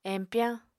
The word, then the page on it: empyañ: Apatani